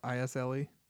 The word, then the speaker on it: Isle: I'm saying Isle. I S L E. Yeah, it's Isle.